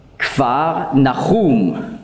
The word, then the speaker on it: Kfar Nachum: Kapernaum, hebräisch ausgesprochen, Kfar Nachum, bedeutet so viel wie Dorf des Trostes.